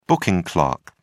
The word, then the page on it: booking clerk: British English